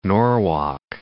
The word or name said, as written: Norwalk